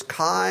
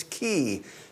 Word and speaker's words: chi: And half of them will tell you it's chi, half will tell you that it's ki. So either one is an acceptable pronunciation.